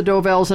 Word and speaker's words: Dovells: Delvaux. – Number six. – Not saying it right. – That's all right. You know it's the Dovells, and I'll give it to you for that.